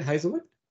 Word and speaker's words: Heazlewood: So let's go into what you actually do and let's go into how does Fleur Heazlewood, is that how you say it, Heazlewood? – Yep, we pronounce Heazlewood, yeah.